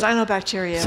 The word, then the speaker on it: cyanobacteria: Did you say cyanobacteria? Is that how you say it? – Cyanobacteria.